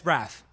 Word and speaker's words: Braff: Is it Braff? – Yeah. – But I'm a fan of his. I'm a fan of his. I've been saying his name wrong. – Yeah, it's Braff.